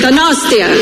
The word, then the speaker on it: Donostia: Donostia. I'm trying. – Venga. – Eskerrik asko, gosh. Okay. All right. Eskerrik asko, gosh. How do I say it? Donostia. – Donostia.